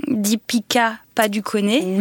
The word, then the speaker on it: Deepika Padukone: Deepika Padukone.